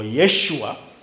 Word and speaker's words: Yeshua: The Hebrew pronunciation or spelling is Yehoshua or Yeshua.